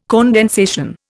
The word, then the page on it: condensation: Indian